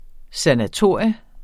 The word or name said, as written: SANATORIET